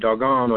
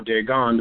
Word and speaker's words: Dagon: Now, that also ties back to the priesthood of Dagon or Dagon, depending on how you want to pronounce it.